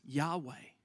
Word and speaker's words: Yahweh: You can say that we pronounce it in modern terms Yahweh, I am.